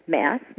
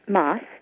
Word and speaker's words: Mass: It's pronounced Mass, like Massachusetts, not Moss. Not sure why people pronounce it as Moss, but many people do, but it's Mass.